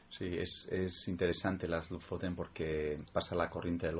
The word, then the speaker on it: Lofoten: Lofoten, ¿no? – Lofoten,